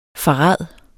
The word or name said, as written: farad